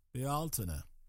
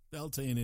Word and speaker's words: Beltane: I once read that it should be pronounced Bealtana. Beltane it is.